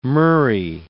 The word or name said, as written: murrey